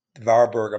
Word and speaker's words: Warburg: Warburg, I'm saying, it's trying to say it in German. It's spelled W-A-R-B-U-R-G, right?